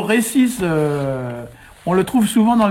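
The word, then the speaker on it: resis: Moi je l'écris avec un seul S parce que c'est pas nécessaire d'en mettre deux, puisque en breton le S se prononce toujours S, même s'il est entre deux voyelles, donc autant aller au plus simple. D'ailleurs, on le voit souvent écrit, récis aussi, avec un seul S.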